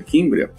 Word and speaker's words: Cimbria: que é a marca Kimbra, escreve-se Simbra, mas pronuncia Kimbria, são máquinas projetadas para nos entregar uma alta performance de resultado final de qualidade do beneficiamento.